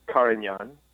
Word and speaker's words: Carignan: It was 80% Zinfandel, 11% Carignane. How do you say that? – Carignan.